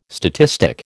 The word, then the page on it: statistic: American English